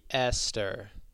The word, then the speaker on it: Esther: Yeah, so the pronunciation here is going to be Esther. – Like Perel. – a normal Esther. – Esther. – Right, Esther.